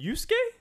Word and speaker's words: Yusuke: Haru and Yusuke? – Oh my god. – Did I pronounce any of those correct? – Yes, that was perfect.